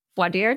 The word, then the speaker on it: voir dire: Voir dire. Did I say that right? Correct.